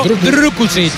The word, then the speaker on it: Drukušić: Hay que pronunciarlo Drukušić. – Hay que poner la boca así, como si.